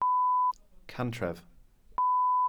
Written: cantref

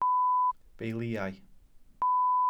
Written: beiliau

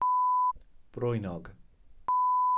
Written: brwynog

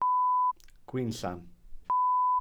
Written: gwinllan

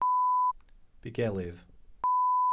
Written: bugelydd

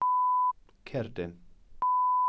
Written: cerdin

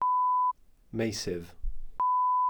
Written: meysydd

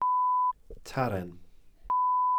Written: tarren

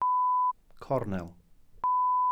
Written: cornel

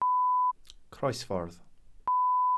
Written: croesffordd

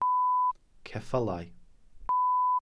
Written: ceffylau

